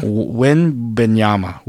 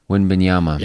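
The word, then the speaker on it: Wembanyama: Victor Wambiana? – Wimbanyama. – Wimbanyama.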